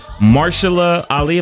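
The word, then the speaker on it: Mahershala Ali: On the movie tip real quick, because I know we have movies, Marshala Ali, am I saying his name right? – Yes.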